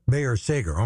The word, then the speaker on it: Bayer Sager: Music by Carol Bayer Sager. Did I ever say that right? Bayer Sager, okay.